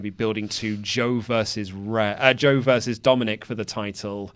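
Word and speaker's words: Dominik: I'd imagine there'll be some Dominic, did I say that right? – Yeah, Dominic shenanigans.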